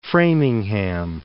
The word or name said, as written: Framingham